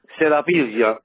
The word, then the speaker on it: Serapiglia: Serapiglia. It's a southern Italian name. It's half Roman and half Greek.